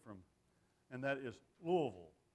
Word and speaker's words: Louisville: If it sounds like you got a rock in the middle of your tongue, it's you're saying it right, Louisville.